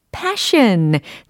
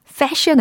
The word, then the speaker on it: passion: Follow your own passion. 잘하셨습니다. Fashion 아니죠? passion이라고 발음해 주시고요.